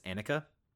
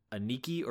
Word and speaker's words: Annika: It is Annika, not Aniki or Anika or whatever the fuck I called it last time. It's Annika.